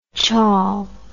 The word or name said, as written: chawl